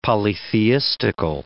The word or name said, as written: polytheistical